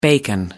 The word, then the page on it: bacon: American English